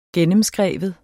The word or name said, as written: gennemskrive